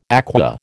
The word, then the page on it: aqua: American English